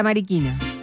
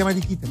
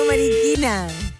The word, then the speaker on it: Mariquina: De la Mariquina. – ¿De la qué? No se dice eso. No se dice Mariquita. – No, Mariquina.